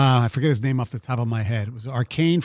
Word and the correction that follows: Achane: from Texas A&M. Is that the way you pronounce his name? – Yeah, Devin Achane. – Yeah, Achane.